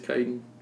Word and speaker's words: chitin: How do you say that word? What's that word? – Chitin. – It's chitin.